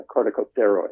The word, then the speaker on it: corticosteroid: the corticosteroid. I think I'm saying that right. – You are. So well done.